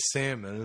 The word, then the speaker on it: salmon: Bears don't know that it's silent. – They just read the signs that the DNR put up. – That's a foolish way to spell things. These guys say it wrong. There's an L there. What are these idiots? – You know, I heard one of these idiot rangers the other day. He's calling it salmon.